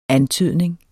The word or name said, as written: antydning